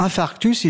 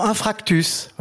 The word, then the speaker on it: infarctus: Hein, pour infarctus, ils diront infractus, voilà.